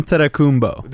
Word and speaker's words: Antetokounmpo: Antetokounmpo. – There you know we go. As far as I'm concerned, that was a B there, not a P.